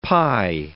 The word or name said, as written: pi